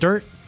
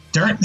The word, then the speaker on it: Dirnt: I started playing bass before I played guitar, and I learned from Mike Dirnt. Like, is that how you pronounce it? Dirnt? – Dirnt, yeah, Dirnt.